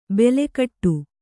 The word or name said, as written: ಬೆಲೆಕಟ್ಟು